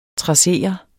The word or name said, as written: trassere